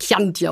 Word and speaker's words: Chianti: Es wird natürlich nicht Chianti ausgesprochen, es wird Chianti ausgesprochen.